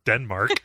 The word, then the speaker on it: Denmark: a drove of clowns could take over Denmark. I don't know why I chose Denmark. It was just the funniest one. It's got the hard K sound at the end.